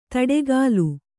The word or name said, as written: ದಾಟುಗಾಲು